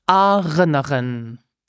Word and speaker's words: erinnern: Of course, we will start with step number 1 and practice the correct pronunciation of the verb remember, which is erinnern.